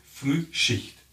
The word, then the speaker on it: Frühschicht: Frühschicht. Frühschicht. It's like the early shift.